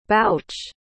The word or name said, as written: bouch